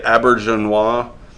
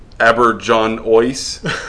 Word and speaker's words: Auberjonois: Renee Auberjonois, I believe that's how you pronounce it. – That or you're just showing off. – Auberjonois.